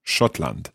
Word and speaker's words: Schottland: Schottland.